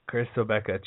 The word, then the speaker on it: Obekpa: But the biggest thing about them is Chris Obikike, I'm going to butcher the name, Chris Obikike, Obekpa, oh jeez, O-B-E-K-P-A, that guy, Chris Obekpa, is suspended.